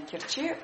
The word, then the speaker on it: Керчи: Керчи, говорят местные жители, они по-другому делают ударение. Нам привычнее, наверное, Керчи, но это такой спорный момент.